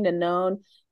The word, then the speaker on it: Nanon: I think is the pronunciation of her name, Nanon.